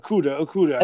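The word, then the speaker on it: Okudah: Obviously, they got the corner, Okuda. Okuda, I think that's how you say his name. – Yeah. Okuda.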